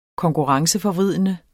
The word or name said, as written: konkurrenceforvridende